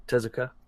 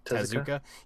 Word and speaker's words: Tezuka: And same thing with I can't ever say his name, the Mario guy, Tezuka. – Tezuka.